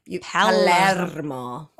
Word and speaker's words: Palermo: I know, so you've Josephine got to roll the R. – Palermo.